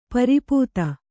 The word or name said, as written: ಪರಿಪೀತ